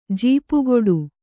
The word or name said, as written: ಜಪಗುಟ್ಟು